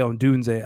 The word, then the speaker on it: Odunze: How do you say his last name? I always butcher Romeo Odunze. – Odunze? – Yeah, Odunze. I was like, man, so speaking of Odunze, so Greg holds up the draft.